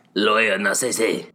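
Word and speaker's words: Lowell Narcisse: But what it does mean, so Miles Brennan against our guy, our Frenchman, Loyal Narcisse. – Lawyer Narcisse.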